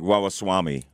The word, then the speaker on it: Ramaswamy: Vivek Ramaswamy.